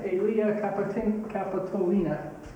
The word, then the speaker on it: Aelia Capitolina: Aelia Capitolina.